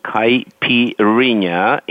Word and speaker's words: Caipirinha: Yes, it's called Caipirinha, and the spelling is C-A-I-P-I-R-I-N-H-A.